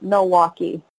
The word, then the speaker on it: Milwaukee: But I guess, how did we say it when I was growing up? Milwaukee. – Milwaukee. You'd pronounce the L, yes. – No, yeah, it was more of an aw sound, too.